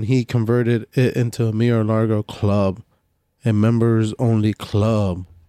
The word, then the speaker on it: Mar-a-Lago: Trump, primary a businessman and a real estate investor at the time, Mar-a-Lago. Is that how you say it? – Mar-a-Lago.